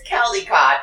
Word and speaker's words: Caldicott: So it's Caldicott. It's C-A-L-D-I-C-O-T-T.